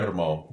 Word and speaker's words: Guillermo: How do you say his first name? – Guillermo. – Guillermo? Yeah. Guillermo Del Toro.